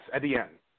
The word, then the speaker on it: Etienne: Is it Etienne? Is that how you pronounce it? – Yes, Etienne.